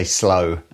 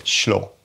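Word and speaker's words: Slö: Have you tried the Walrus, I'm going to say slow, and it's not the correct pronunciation because there's like an umlaut or whatever you call it across the F, schlo or something, I think it is.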